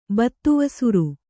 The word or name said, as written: ಬತ್ತುವಸುರು